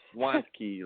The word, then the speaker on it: Wandtke: Yeah, it's just Wandtke, like I want a key.